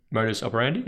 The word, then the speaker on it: modus operandi: modus operandi.